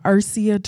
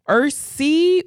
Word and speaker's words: ERSEA: So what's in the Head Start world, Ursia or Ursia, depending on where you're from.